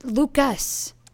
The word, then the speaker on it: Lucas: It's Lucas. He hates it when... It's not like a French thing where you don't pronounce... Lucas. – Fine, Lucas.